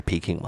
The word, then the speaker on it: Peking: 然后北京大学的话就是 Peking 嘛，就是 P-E-K-I-N-G。